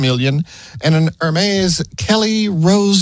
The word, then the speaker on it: Hermes: It's an original Hermes Birkin. Auctioned at Sotheby's Paris back in July of last year. – Pretty sure that's pronounced Hermes, but go on.